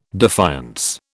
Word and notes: defiance: British English

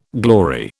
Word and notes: glory: British English

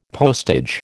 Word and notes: postage: American English

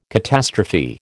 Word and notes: catastrophe: American English

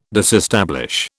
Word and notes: disestablish: British English

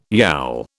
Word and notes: yowl: British English

